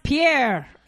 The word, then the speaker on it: Pyer: But I did, like I told you, I did read that article that it is pronounced. It was a five things you should know about P-Y-E-R-M-O-S-S. It's pronounced Pierre.